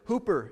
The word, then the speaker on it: hyper: But instead of it just being nikao, he's added on to it hooper. It's spelled hyper, H-Y-P-E-R, but it's pronounced hooper, nikao.